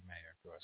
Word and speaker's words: Meyer: It's spelled Meyer, but I feel like we've seen clips of her say Mayer. – She does say Mayer.